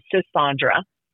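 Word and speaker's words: Sisaundra: If you all would please follow me at Sassandra. My name is spelled S as in Sam, I-S-A-U-N as in Nancy, D-R-A, Sassandra.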